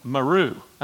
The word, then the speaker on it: Meru: Well, we watched a documentary on these three guys, and the title of the movie is called Meru. I think that's how you pronounce it, M-E-R-U.